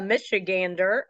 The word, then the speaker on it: Michigander: So your journey is you were a Michigander. Mm-hmm. Did I say that right? – Yep.